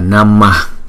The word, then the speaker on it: Namah: Cái chữ Namah này chúng ta đọc là Namah, Namah, Namah.